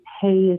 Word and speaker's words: Hey: So just going on Amazon, searching my name, Cynthia, and Hey is spelled H-E-Y, like Hey You.